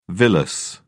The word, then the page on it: villus: British English